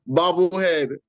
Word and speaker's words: bobblehead: Is it bobble or bubble? – Man, whichever, you understood what I said. How do you say it? – Bobblehead. – Bobblehead. Okay, I stand corrected, man. I always say bobblehead, man. Bobblehead. Okay, cool.